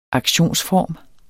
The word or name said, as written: aktionsform